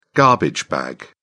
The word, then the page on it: garbage bag: British English